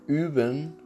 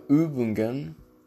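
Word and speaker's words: üben: این او همون مثل یو نوشته میشه اما دو تا نقطه بالا سرشه، صدای همون که گفتم او میده، مثل اوبن یا اوبنگن.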